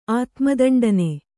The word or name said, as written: ಆತ್ಮದಂಡನೆ